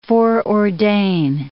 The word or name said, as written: foreordain